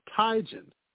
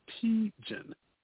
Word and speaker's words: Teigen: And a few years back, Christy Teigen announced that she, we have been saying her last name wrong for years. It's pronounced Teigen, not Teigen.